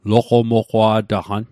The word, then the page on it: loqomoqwa'taqan: Mi'gmaq